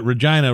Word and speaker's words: Regina: Because I don't know if you noticed, but Regina rhymes with certain things. – Lady parts. Yeah.